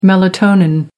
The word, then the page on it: melatonin: American English